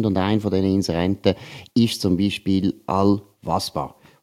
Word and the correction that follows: Alva Spa: Oder besser gesagt, Alva Spa. Ich habe es eben falsch ausgesprochen, tut mir entschuldigen für das, ist ja logisch.